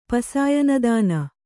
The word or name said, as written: ಪಸಾಯನದಾನ